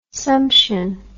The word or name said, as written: sumption